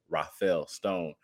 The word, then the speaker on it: Rafael Stone: And we talk about Rafael Stone so little that I'm pronouncing his name like he's one of the Ninja Turtles, and I apologize for that.